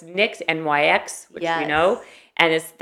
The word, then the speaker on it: Nyx: Oh, so it's Nyx, N-Y-X, but it's pronounced Nyx. – Nyx.